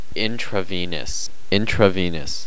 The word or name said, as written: intravenous